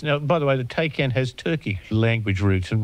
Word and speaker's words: Taycan: I might have said a Taycan, but it's not that, is it? – No, it's a Taycan. – Well, T-A-Y-C-N.